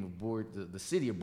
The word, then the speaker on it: Borger: How do you pronounce it? – Borger. – Borger. – B-O-R-G-E-R. Tip of the Panhandle.